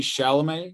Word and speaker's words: Chalamet: and that's Timothy Chalamet.